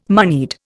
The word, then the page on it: moneyed: Indian